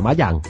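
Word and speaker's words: Mayang (麻将): El solitario Mahjong, el Mahjong, pronunciado en chino Mayang.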